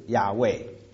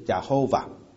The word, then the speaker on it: 亚卫: 那当然我说我想师母已经说过了，这个耶和华这个中文的翻译呢，更准确的翻译应该是，这个发音应该是亚卫，亚卫不是亚侯瓦啊，那是过去的时候我们不太知道这个字该怎么发音，因为当时在旧约圣经的希伯来文它没有赋予这个母音。